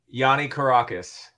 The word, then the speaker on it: Yianni Kourakis: How do you pronounce it? – Yianni Kourakis.